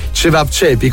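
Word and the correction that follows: ćevapčići: com'è che si pronuncia? – Cevapcici. – Cevapcici, ok, cevapcici.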